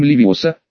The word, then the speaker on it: Левиоса: Правильно говорить с ударением Левиоса, а не Левиаса.